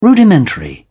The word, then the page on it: rudimentary: British English